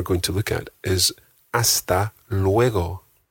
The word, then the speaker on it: hasta luego: The G in hasta luego is a very soft G. It's not luego, it's luego.